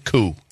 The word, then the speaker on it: Koo: And then his last name is Koo. K-O-O.